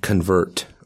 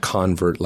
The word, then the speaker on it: convert: So the book is pronounced convert, like a verb, not convert, like a noun.